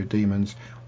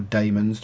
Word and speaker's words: Daemons: Demons, or Daemons, depending on how you want to pronounce it, and several orphaned first Doctor stories.